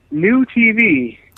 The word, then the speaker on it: NuTV: New TV. – And how is that spelled? – It's spelled N-U-T-V. The first time I read it, I thought it said Nut V. What the hell's a Nut V?